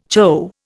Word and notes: chough: Indian

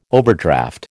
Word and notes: overdraft: American English

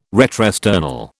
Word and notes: retrosternal: British English

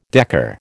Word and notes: decker: American English

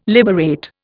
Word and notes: liberate: Indian

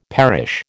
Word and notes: perish: American English

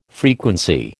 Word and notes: frequency: American English

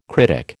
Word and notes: critic: American English

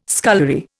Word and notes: scullery: Indian

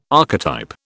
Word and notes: archetype: British English

